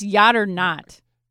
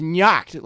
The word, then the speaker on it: Nyacht: I think it's pronounced Yacht or Not. – No, it's Nyacht, like, because, you know, it's N-Y-A-C-T.